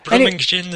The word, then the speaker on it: Birmingham: It's morning, actually, over in Burlingham. Do I pronounce that right? Burlingham? – Never heard it pronounced that way before. – Oh, okay. – Birmingham and things like that, but usually just Birmingham.